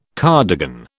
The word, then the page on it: cardigan: British English